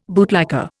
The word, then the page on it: bootlicker: Indian